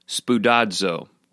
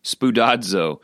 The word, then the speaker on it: spoudazo: It's pronounced spoudazo, which is actually really fun to say. I recommend right now wherever you are, you say the word spoudazo.